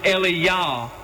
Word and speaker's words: Elijah: It should be pronounced Eliyah, and it means, "My mighty one is Yahweh."